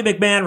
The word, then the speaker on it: McMahon: And Bobby McMahon rhymes with Jared McCann.